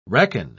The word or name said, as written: reckon